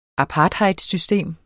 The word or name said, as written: apartheidsystem